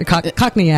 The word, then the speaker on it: Cockney: They turn into they get Cagney accents, Cockney accents.